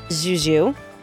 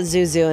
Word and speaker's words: Zuzu: I always call it Zuzu, and it's not enough zh in the middle of it. – You need yes. The Frenchy part.